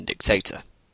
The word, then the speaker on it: dictator: I'm sure you're asking yourself why I am saying dictator rather than dictator. This is for several reasons, such as that is the actual pronunciation. It is a long A rather than a short A.